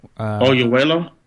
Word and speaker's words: Oyelowo: The first one is, and I'm going to try and say his name right, David Olovi. – Oyelowo? – Yeah, he was in The Butler.